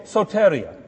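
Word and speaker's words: Soteria (σωτηρία): I'm not a Greek major, but I think it's soteria. Is that right? Soteria? Accent in the right place? On the right syllable? Soteria. Soteria, like cafeteria. There's got to be a connection. You've always got these connections. Cafeteria, soteria, right?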